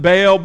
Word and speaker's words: Baal: We sometimes pronounce it Baal, but in Hebrew it's pronounced Baal.